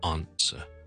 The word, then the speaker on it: answer: 听一听个发音先，系 answer。 OK，佢个发音其实系同美式英文系争好远嘅。